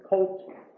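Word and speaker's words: Kult: In his view, the reform of Josiah was concerned with cult reinheit. – Now, that's a German word, cult. You can, just like the English word, C-U-L-T, it's spelled with a K, cult.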